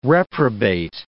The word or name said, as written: reprobate